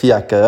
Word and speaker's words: Fiaker: Da gibt's den Verlängerten, es gibt den Braunen, es gibt den Einspänner, es gibt den Kapuziner, Melange, Franziskaner, und Fiaker, habe ich das jetzt richtig ausgesprochen? – Fiaker, ja.